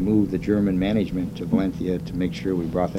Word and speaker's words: Valencia: Is that the Castilian pronunciation? – Valencia. – Yeah, right. – Yes. – That's good. – Yeah. Sounds like you're lisping.